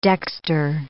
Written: dexter

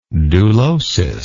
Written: dulosis

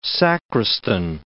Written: sacristan

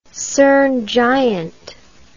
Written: cerne giant

